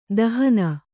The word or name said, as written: ದಹನ